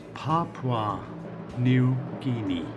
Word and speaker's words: Papua New Guinea: It's pronounced Papua New Guinea.